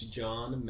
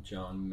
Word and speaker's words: John: So it's John Manna Music. My last name is spelled M-A-N-A. John Manna. – John with the H. – J-O-H-N. – Not that J-O-N.